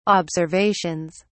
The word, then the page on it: observations: British English